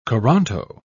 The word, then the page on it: coranto: British English